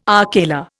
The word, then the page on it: akela: Indian